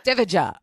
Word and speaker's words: Divija: DIVIJA.